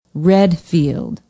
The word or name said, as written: readfield